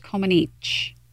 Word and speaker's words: Comaneci: It's pronounced Comaneci.